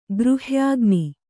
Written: ಗೃಹ್ಯಾಗ್ನಿ

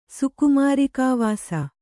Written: ಸುಕುಮಾರಿಕಾವಾಸ

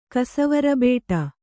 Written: ಕಸವರಬೇಟ

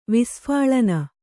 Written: ವಿಸ್ಫಾಲನೆ

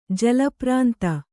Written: ಜಲಪ್ರಾಂತ

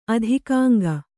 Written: ಅಧಿಕಾಂಗ

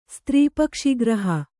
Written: ಸ್ತ್ರೀಪಕ್ಷಿಗ್ರಹ

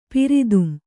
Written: ಪಿರಿದುಂ

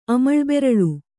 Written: ಅಮಳ್ಬೆರಳು

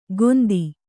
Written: ಗೊಂದಿ

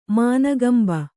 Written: ಮಾನಗಂಬ

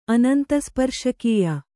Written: ಅನಂತಸ್ಪರ್ಶಕೀಯ

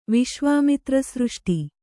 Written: ವಿಶ್ವಾಮಿತ್ರಸೃಷ್ಟಿ

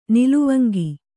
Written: ನಿಲುವಂಗಿ

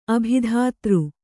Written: ಅಭಿಧಾತೃ

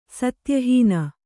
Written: ಸತ್ಯಹೀನೆ

